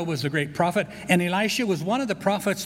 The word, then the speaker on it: Elisha: And Elisha, E-L-I-S-H-A, Elisha, as Diane pronounced.